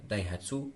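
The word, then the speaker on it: Daihatsu: فيها دونك تويوتا فيها ليكسوس فيها زاد دالهاتسو مي دالهاتسو ما دايهاتسو ما كيفاش تنطق دايهاتسو اي دايهاتسو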